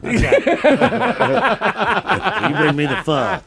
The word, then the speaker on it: pho: Pho. – Pho. – Pho. – Pho. – Pho. – Pho. Pronounced as the Vietnamese themselves do it, pho.